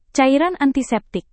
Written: cairan antiseptik

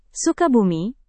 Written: sukabumi

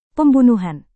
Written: pembunuhan

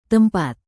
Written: tempat

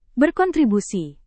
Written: berkontribusi

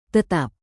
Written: tetap

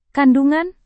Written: kandungan

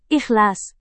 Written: ikhlas

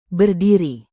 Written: berdiri

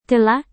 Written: kelak

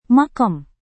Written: makam

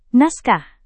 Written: naskah

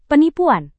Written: penipuan

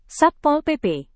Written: satpol pp

